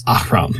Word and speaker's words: Ahroun: In my own games, I don't make that much of a distinction, but anytime I say it, or an NPC says it, I try to say it as Ahroun.